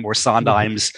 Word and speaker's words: Sondheim: And then the other thing was, just to clarify, I said that I was under the strong impression that Sondheim's last name should be pronounced without the H aspirated, because everybody I know who's worked very closely with him says Sondheim.